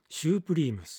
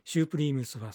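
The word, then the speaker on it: Supreme: はい、 12 曲目は You Keep Me Hanging On シュープリームス、まあアメリカ的にはスプリームスというそうですが、 1966年、昭和でいうと 41年、シュープリームスは最高のという意味で、アメリカではスプリームと発音する人が多いということです。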